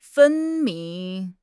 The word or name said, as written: 分明